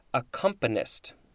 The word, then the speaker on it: accompanist: Concomitant is a synonym, C-O-N-C-O-M-I-T-A-N-T. Accompanimental is the adjective. Accompanimist, am I saying that right? Accompanist. That just sounds kind of weird. Accompanist, noun circa 1811.